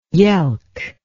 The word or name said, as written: yelk